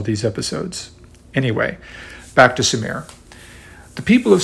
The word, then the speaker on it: Sumer: Growing up and reading about Sumer, I read somewhere that the correct, correct pronunciation was Shumer, with an SH at the beginning. However, in my recent preparation for this episode, I cannot find any such pronunciation. Instead, people seem to pronounce it as it reads, Sumer, with a simple S you may have heard me alternate these pronunciations in earlier podcasts when I made future reference to this ancient realm.